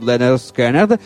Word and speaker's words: Lynyrd Skynyrd: Poco dopo la morte di Dwayne, Ronnie Van Zant, dei mitici Lynyrd Skynyrd, dei grandi, to be pronounced, Lynyrd Skynyrd, altri grandi, geniali maestri del Southern Rock, nel 1973 gli dedicarono la canzone Free Bird, proprio alla sua memoria.